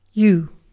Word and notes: you: British English; stressed

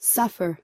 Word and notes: suffer: American English

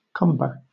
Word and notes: cumber: British English

